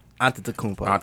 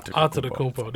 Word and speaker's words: Antetokounmpo: The Nigerian pronunciation is Antetokounmpo. – See, there you go. All right. – The Greek one is Antetokounmpo. – Antetokounmpo.